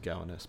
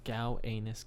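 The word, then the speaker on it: Gowanus: I think it's just pronounced Gow Anus, but Gow Anus is way cooler.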